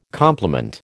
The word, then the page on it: compliment: American English